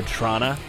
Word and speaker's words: Toronto: To Trana. – The correct way to spell... The way to spell the correct pronunciation of Trana is C-H-R-A-N-A. – Yeah. – Maybe a double N at the end, like Trana.